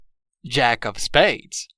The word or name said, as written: jack of spades